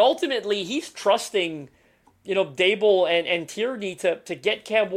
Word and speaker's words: Daboll: Yeah, so it's Daboll. D, like table. – I played that because Hershey throws Daboll in the chat. It's Daboll, like table. Obviously.